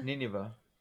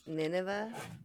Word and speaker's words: Nineveh: So he was in, how do you say it, Nivea? – Nineveh. – Nineveh.